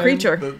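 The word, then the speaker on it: Kreacher: Creature.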